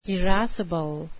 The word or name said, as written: irascible